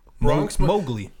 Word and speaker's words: Mowgli: Mogili? Mogil? – Bronx Mowgli.